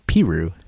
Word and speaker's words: Peru: There's a town in Indiana, Peru. – I'm guessing it's not pronounced Peru. – It's Peru.